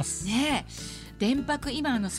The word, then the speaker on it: 伝泊: 三菱電機プレゼンツ戸田恵子大人クオリティ今日フォーカスするのは電白です伝えるに止まると書いて電白、